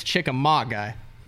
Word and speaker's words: Chickamauga: So Chickamauga, and it is Chickamauga.